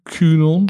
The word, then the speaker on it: QAnon: En dat zien we nu met nou ja, ik, ik kan het nooit goed uitspreken. Quenon, QAnon. QAnon. QAnon heet het, ja.